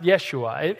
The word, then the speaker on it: Yeshua: In Hebrew, it means Yeshua, or it's pronounced Yeshua.